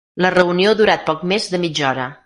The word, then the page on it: durat: catala